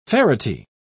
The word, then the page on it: ferrety: British English